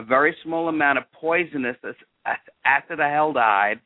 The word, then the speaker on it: acetaldehyde: Yeah, with an emphasis on the tal. Acetaldehyde.